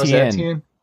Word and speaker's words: Etienne: Since it was Etienne, Travis Etienne.